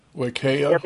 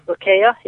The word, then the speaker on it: Wakaya: How would you pronounce this? W-A-K-A-Y-A, Club and Spa? – W-A-Y-A-K. – No, sorry, W-A-K-A-Y-A. – Wakea. – Yes. Wakea, yeah.